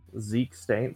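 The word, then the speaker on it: Zeke Stane: One I think that if you pull in sealed that you should probably play because it's a solid piece is I'm probably gonna say it wrong, and I'm sorry for anybody that's a huge Marvel fan and knows comic books, is 029 Zeke Stain. – Zeke Stain, that's good. That's fine.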